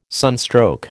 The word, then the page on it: sunstroke: American English